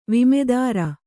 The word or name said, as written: ವಿಮೆದಾರ